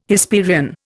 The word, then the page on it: Hesperian: Indian